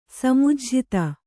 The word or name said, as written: ಸಮುಜ್ಝಿತ